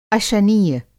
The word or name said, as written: अशनीय